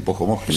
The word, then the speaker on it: Pojomoski: Muy bien, hoy con frío, hoy tuvimos que sacar, no digo el Pojomoski, porque no sé si lo dije bien, pero... – No, es exactamente así, Pojomoski.